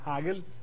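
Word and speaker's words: Hegel: Ya hemos visto que se llama Hagel, así se pronuncia, con H, y es una falacia que no tiene realidad.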